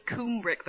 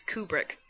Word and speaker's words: Kubrick: I always say his name wrong because I just talk weird. I say Kubrick, but it's Kubrick.